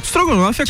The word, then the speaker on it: estrogonofe: a, a, pronúncia certa, a pronúncia certa é estrogonofe, né? – Estrogonofe. Né? Porque ele é russo.